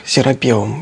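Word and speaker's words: Серапеум: Я просто для уважаемых зрителей напомню, ну, во-первых, чтобы зрители не ждали, что я сейчас буду рассказывать опять про Серапиум, все-таки я настаиваю, что ударение ставится в этом слове так, Серапиум.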